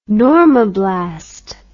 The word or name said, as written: normoblast